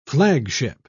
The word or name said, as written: flagship